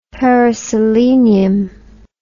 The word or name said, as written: periselenium